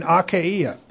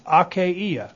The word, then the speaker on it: Achaia: By the way, it's Achaia. If you were wondering if it was Achaia. It's not Achaia, it's Achaia. Okay? Look in the book. look in the Greek pronunciation book. And you'll see that that's why I keep calling it Achaia.